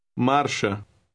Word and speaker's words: marcha: Word 9 has an R in the middle. It is a rolling R, but pronounced very lightly. Try word 9. – Marcha.